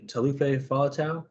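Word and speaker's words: Taulupe Faletau: Four, Mauro Itoje, five, Ian Henderson, six, Josh Navidi, seven, Tom Curry, eight, how do you say that name? Talufe Fa'atau? Is that right? – That's pretty good, yeah.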